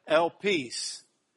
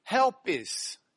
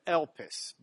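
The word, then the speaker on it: elpis (ἐλπίς): You'll see that there's an E as the first letter, and it's got a comma turned the correct way, so you do not pronounce it as an H. If the comma were turned the other way, we'd pronounce it helpis instead of elpis, but it's just elpis.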